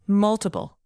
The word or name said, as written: multiple